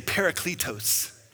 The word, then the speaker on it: parakletos: Say parakletos. It's like if you were to say, put a pair of cleats on and then add some Mexican Spanish tacos at the end. So, parakletos, that's how you say it.